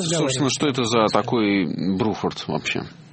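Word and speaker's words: Bruford: И называли этого человека Бруфорд, в то время как правильно его называть Бруфорд.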